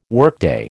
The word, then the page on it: workday: American English